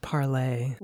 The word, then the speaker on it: Parler: Parler. We'll see you on Parler. Follow our phone news on Parler. – Unfortunately, it's supposed to be pronounced parlay.